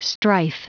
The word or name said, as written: strife